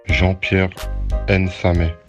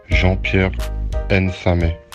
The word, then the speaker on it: Jean-Pierre Nsame: Jean-Pierre Nsame. Jean-Pierre Nsame. Jean-Pierre Nsame.